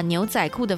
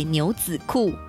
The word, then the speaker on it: 牛仔裤: 制作这集节目的时候，有年轻的配音伙伴提醒我，教育部已经把牛仔裤的发音正式修改为牛仔裤，所以现在的中学生都要说牛仔裤才是正确发音。